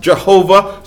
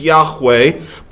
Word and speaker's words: Yahweh: It's sometimes pronounced Jehovah, sometimes Yahweh, but it's the name by which he revealed himself to his people.